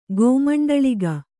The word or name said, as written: ಗೋಮಂಡಳಿಗ